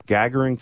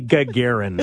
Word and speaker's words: Gagarin: It'll be Gagarin. – Gagarin Cup. – Because the emphasis is, you know better than anybody. You deal with them every year now. – Yeah, I know. – You know where the emphasis in the Russian language is. It's always on the second syllable.